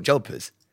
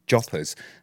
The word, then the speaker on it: jodhpurs: That's what they're called, jodhpurs. – Joppers. – No, they're not. They're jodhpurs. – Yes, that's how it's spelt, but joppers. It's the same as nat. Like, you don't call it, it's spelt gnat, but you say nat. – Jodhpurs. – Joppers. – No, it's jodhpurs. – Joppers.